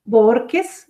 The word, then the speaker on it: Bohorquez: Naidu se escribe N-H-A-Y-D-U Borques B-O-H-O-R-Q-U-E-Z,